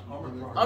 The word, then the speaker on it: Omicron: Omicron.